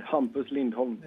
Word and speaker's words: Hampus Lindholm: Is Luke pronouncing that correctly? – No, I know I'm not. – No, of course not. – That wasn't too bad. Hampus Lindholm. – Yeah, see, all right, that's pretty close.